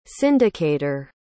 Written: syndicator

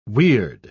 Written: weird

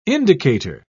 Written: indicator

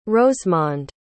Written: rosemonde

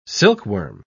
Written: silkworm

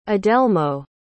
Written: adelmo

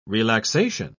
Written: relaxation

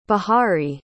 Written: bahari

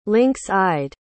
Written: lynx-eyed

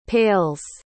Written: pales

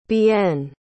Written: Bienne

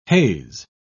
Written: haze

